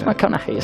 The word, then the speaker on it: McConaughey: Es McConaughey.